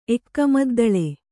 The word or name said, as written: ಎಕ್ಕಮದ್ದಳೆ